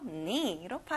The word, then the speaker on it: knee: 친구들, 무릎에는 K 소리가 안 나요. 그래서 knee로 발음하면 좋아요.